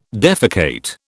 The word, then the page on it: defecate: British English